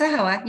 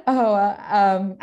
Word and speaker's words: AAHOA: Yep. No. It's AAHOA. You're correct. – AAHOA.